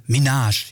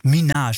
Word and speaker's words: Minaj: Minaj. Ich könnte wieder wetten, ich habe es wieder falsch ausgesprochen. Aber Minaj, jetzt habe ich es. Minaj, so war's.